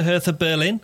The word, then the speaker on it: Hertha Berlin: He's going back to Hertha Berlin, to pronounce it correctly.